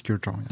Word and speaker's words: Kurkdjian: And I'm very happy to welcome into the studio today the CEO of Maison Francis Kurkdjian. Am I pronouncing it right? – You are. It's CEO and co-founder, actually, of Maison Francis Kurkdjian.